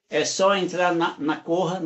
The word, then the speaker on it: Koha: Corra, K-O-H-A. É assim que se escreve esse, ah, o, o, o nome da Corra, dessa, desse site de inscrição. Isso. K-O-H-A. Corra. Lá tem a corrida.